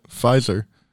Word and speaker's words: Pfizer: Yeah, the P is silent. Pfizer.